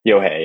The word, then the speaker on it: Yohei Nakajima: Yohei Nakajima, maybe? Something like that? – Yohei, yeah.